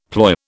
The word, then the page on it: ploy: British English